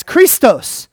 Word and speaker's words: Christos: Do you know how it sounds? What's it sound? It's Christos. Christos.